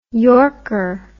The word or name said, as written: yorker